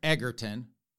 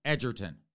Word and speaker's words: Egerton: And I only know that because we did a story on him a couple weeks ago, and I was pronouncing it Taron Egerton, which is how his last name looks. But I was corrected nicely over there on YouTube that his name is properly pronounced Taron Egerton.